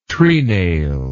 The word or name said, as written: trenail